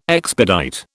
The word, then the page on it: expedite: British English